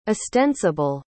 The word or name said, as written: estensible